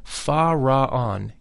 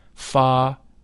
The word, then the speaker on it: Fa-Ra-An: The whole mantra, Fa-Ra-An, is divided into three syllables, thus Fa-Ra-An. The Fa is that of the musical scale. The Ra is a deep sound. It should be vocalized with a rolling R, Ra.